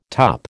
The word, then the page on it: tup: American English